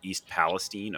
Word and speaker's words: East Palestine: East Palestine, Ohio, which, as you said, is pretty close to Cleveland.